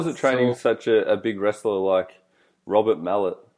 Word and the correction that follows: Maillet: Maillet. – Maillet.